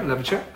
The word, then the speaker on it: Louverture: And so he talks about, I don't want to get this name wrong, but it's Toussaint Louverture. Is that right? – Louverture.